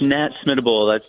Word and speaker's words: Nat Smitobol: It's Nat Smitobol.